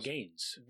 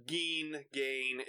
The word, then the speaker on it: Gein: Is it Gein or Gaines? – Gein. It's G-E-I-N, I believe, is how it's spelled.